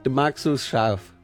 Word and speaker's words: Du magst es scharf: How do you say it again? – Du magst es scharf. – Du magst es scharf.